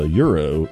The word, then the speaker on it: Euro: Before that was Oiro, O-I-R-O, which is the phonetic English spelling of how you say the euro in German.